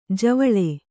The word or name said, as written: ಜೀವಲ